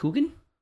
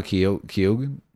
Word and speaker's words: Keoghan: Coogan. – Yeah, no, Keoghan.